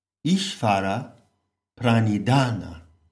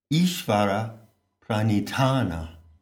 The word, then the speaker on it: Ishvara Pranidhana: Now, in Hindu devotional practices, there is a delicious phrase, Ishvara Pranidhana. Ishvara Pranidhana. It works when you aspirate the last syllable, Ishvara Pranidhana.